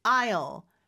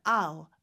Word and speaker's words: I'll: I'll. 근데 이거 이제 빨리 발음하면 아우 이렇게 발음이 네. 되기도 합니다.